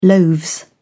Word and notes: loaves: British English

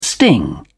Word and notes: sting: British English

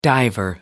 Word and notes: diver: American English

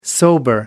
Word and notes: sober: American English

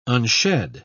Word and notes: unshed: British English

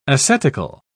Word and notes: ascetical: British English

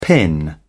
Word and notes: pin: British English

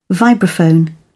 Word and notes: vibraphone: British English

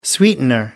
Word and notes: sweetener: American English

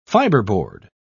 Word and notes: fibreboard: British English

